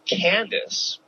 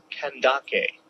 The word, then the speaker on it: Kandake: Now, I've always pronounced this name Candace, but it's actually pronounced Kandake.